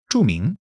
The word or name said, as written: zhu4 ming2